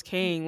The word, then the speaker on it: Kang: Yeah, Kang.